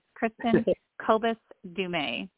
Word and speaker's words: Kristin Kobes Du Mez: It's Kristin Kobes Du Mez.